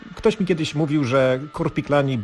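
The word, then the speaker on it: Korpiklaani: Czyta się tak jak napisano, czyli Korpiklani.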